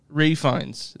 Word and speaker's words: Ralph Fiennes: Actually, it goes Ralph Fiennes. For some reason, they pronounce the L in this, but...